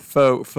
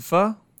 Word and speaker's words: pho: Taekwon Pho is a Thai restaurant with pho. It's a pho? – It's pho. – I think it's pronounced pho, okay.